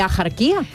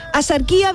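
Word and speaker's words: Axarquía: Escribimos asarquía con X, sí. ¿Vale? Pero ¿cómo lo pronunciamos? ¿Ajarquía? No, yo no conozco a nadie, asarquía. A mí que me registren, pero yo no conozco a nadie que diga ajarquía.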